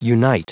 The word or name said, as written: unite